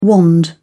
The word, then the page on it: wand: British English